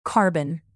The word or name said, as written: carbon